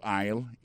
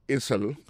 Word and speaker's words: aisle: Go to aisle 11 and you will find it. Aisle là, c'est A-I-S-L-E. Vous prononcez aisle. Il dit, marqué Essel.